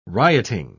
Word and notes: rioting: British English

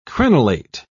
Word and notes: crenelate: British English

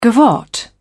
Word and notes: gavotte: British English

